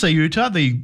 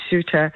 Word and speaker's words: Ceuta: Is it pronounced Ceuta, on the border of Spain, this surge, which it seems strange. So we had, thousands of Moroccans surging across the border, but then turned around and went back? – Well, there were people who are migrants who were trying to get to Ceuta, I believe is the pronunciation, this autonomous city of Spain, and it's on the North African coast, so it's bordered by Morocco.